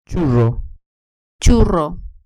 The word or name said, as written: churro